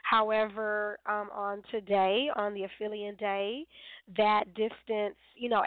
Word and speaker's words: aphelion: July 3rd is the aphelion. I think it's P-H, so it's pronounced with the F, aphelion. But you are, you know, you can look that up, A-P-H-E-L-I-O-N.